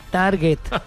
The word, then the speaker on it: target: Las juntas no digan target. – Se dice target. Target, sí. A ver, aquí está el target. No, pendejo, es target. En inglés se pronuncia target.